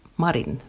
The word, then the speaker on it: Marin: Men hon är ju finländare, alltså finsk, och säger själv marin, mera, med betoningen på den första.